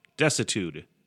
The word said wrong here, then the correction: desuetude: Desitude. That's what I'm going with. Now I'm looking at the pronunciation. Nope, wrong. Desuetude.